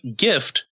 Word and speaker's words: GIF: GIF, just like gift, where just without the T.